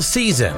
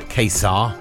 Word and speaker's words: Caesar: Yes, Daniel Caesar is the way you say his name, not Kesar.